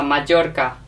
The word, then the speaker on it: Mallorca: Remember the R. Pronounce the R. Mallorca.